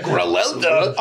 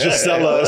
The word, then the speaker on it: Griselda: Oh, yeah, I totally said it wrong. – You did, but I knew what you meant. – I think I said Gisella or something. – No, it wasn't even that. You had no S in there.